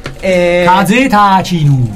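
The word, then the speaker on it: Kazetachinu (風立ちぬ): Kazetachinu. – Kazetachinu, bravo, ecco, la pronuncia, come lo pronunci tu, il giapponese non lo pronuncia nessuno.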